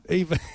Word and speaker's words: Eva: Let's pronounce it in German. Ava Braun lockdown. – Eva.